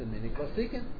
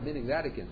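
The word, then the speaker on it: minhag vatikin: Some people think that you pronounce the word minik zadikin. It has nothing to do with minik zadikin.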